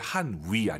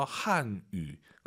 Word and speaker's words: 汉语: 중국어 발음하실 때 한위 아니고 YU 발음은 입술 동그랗게 말아서 한유.